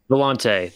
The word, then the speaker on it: Volante: How do you pronounce it? The Volta? For some reason, I was thinking it was a longer word than that. – What? The Volante? – Volante. That's it.